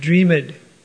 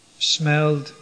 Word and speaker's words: dreamed: A lot of times I hear foreigners pronounce the ED on words that end in ED, like dreamed, smelled, learned. I've heard this, devotees who are from India often do this, devotees from South America do it. But it's not pronounced, it's just smelled, dreamed.